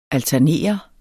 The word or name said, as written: alternere